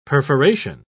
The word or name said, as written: perforation